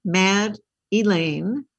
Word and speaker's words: Madelaine: So it's like Mad Elaine, M-A-D-E-L-A-I-N-E. – Okay. – W-E-I.